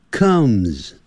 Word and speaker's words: comes: 不要念成 comes，就克，肚子饿的饿，克，饿 comes， so she comes home，她就回家啦。